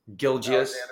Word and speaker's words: Gilgeous: Gilgeous.